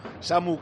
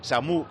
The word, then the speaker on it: Samu: Me pasa Miguel Aguilar un tuit del otro día del Mallorca diciendo: aprovechamos para informar que la palabra Samu no lleva tilde en la U. Samu Costa, ok. Samu Costa, mal.